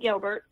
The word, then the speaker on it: Gilbert: Oh, it's just Shani Gilbert, just my name. – How do you spell that? S-H-A-N-E-Y? – Yeah, and Gilbert, G-I-L-B-E-R-T.